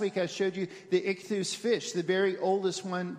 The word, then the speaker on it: ichthus: If you say that phrase in Greek, it's those letters right there, which is pronounced ichthus, and that's the Greek word for fish.